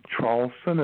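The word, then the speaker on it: Traulsen: It's Hugh, H-U-G-H. – Mm-hmm. – The last name, Charlson, is spelled T like Tom, then R-A, U-L-S like Sam, E like Edward, N like Nancy.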